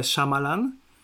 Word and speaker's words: Shyamalan: Szczególnie, że ty jeszcze propagujesz wymowę Shyamalan, kiedy ja z tym takim tak.